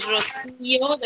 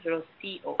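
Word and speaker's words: Rocío: Is Rocio, the accent is on the I, Rocio.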